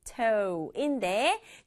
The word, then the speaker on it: tomato: 토마토, 알죠, 아는데 우리말로는 토마토라고 하고 영어로는 발음이 tomato인데 뒤에 있는 T 소리가 약간 약하게 돼서 tomato 이렇게 발음하게 돼요.